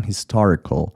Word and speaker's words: historical: دقت کنید بچه ها، کلماتی که در انتها به AL ختم میشن یا ALY یا ALLY در کل، A رو تلفظ نکنید. یک شوای بسیار خفیف است که باز هم میگم اگر تلفظ نکنید خیلی بهتره. پس من نمیام بگم historical، CAL هست آخرش، اما A رو تلفظ نمی کنم و میگم historical.